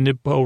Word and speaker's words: Nibpo: The rhyme Nibpo rhymes with toe. Also rhymes with Edgar Allan Poe.